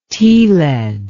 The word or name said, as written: tealead